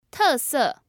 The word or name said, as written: tese